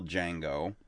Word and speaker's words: Django: Well, the guy who owns the other one is the actor who played the original Django, who originally said, "Yeah, the D is silent," and his name is Franco Nero.